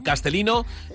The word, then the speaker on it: Castellino: Castellino, ya sabéis, con L, nosotros lo pronunciamos con una L, ella, ella es con L.